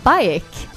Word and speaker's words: Baek: Sen har vi Baek. Jag vet inte hur du uttalar det. Heter han Baek? – Ja, typ B-A-E-K.